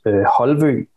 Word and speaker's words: Holvøe: And then he chose Holvøe, and that is totally impossible to pronounce and spell and so on in US.